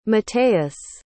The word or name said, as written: matteus